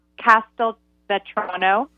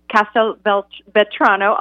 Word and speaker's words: Castelvetrano: In the appetizers, there's a green, like a Castelvanos olive tapenade, Castelvetrano. I always say it wrong. Castelvetrano olive tapenade, like those really buttery green olives.